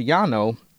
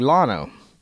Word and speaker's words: Llano: One would think Burnett's neighbor would be called Llano, but it's actually Llano.